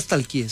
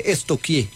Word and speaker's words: stalkear: Porque no se dice nunca stalkeé, se dice estoquié.